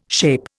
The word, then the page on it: SHAPE: Indian